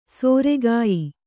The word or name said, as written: ಸೊರೆಗಾಯ್